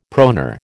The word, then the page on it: proneur: American English